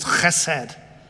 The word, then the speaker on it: chesed: One of the key terms that is used in the scriptures and in the law to describe that is a word that in Hebrew is pronounced this way. You're learning Hebrew this morning. It's chesed, chesed. Practice that at lunch. I'm told by Hebrew professors that you're not really saying it right unless those around you feel a little threatened.